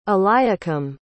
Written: eliakim

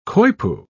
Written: coypu

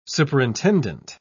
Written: superintendent

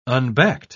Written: unbacked

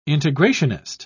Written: integrationist

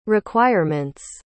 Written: requirements

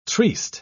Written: triste

